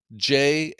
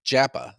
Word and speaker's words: Japa: It is spelled Japa, and in Finland the J is pronounced like a Y.